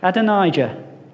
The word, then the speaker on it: Adonijah: Adonijah.